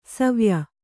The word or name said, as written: ಸಿವಾಯಿ